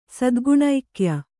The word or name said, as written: ಸದ್ಗುಣೈಕ್ಯ